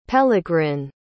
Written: pellegrin

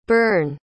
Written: berne

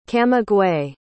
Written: camagüey

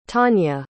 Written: tania

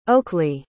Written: Oakleigh

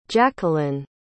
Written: jacquelyn

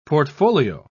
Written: portfolio

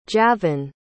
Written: javen

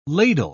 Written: ladle

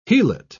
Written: helot

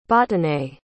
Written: botonée

